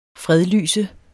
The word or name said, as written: fredlyse